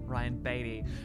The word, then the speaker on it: Ryan Beatty: Ryan Beatty is his name, spelled Beatty, but B-E-A-T-T-Y, but pronounced Ryan Beatty.